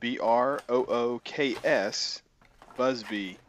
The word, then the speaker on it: Brooks Busby: Brooks Busby, Busby.